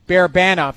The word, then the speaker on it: Barabanov: It's Barbashov or whatever his name is. – Barabanov.